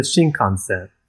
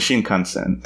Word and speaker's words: Shinkansen: It's like the Shingan? – The Shinkansen. – The Shinkansen. Thank you.